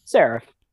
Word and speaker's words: Seraph: Seraph.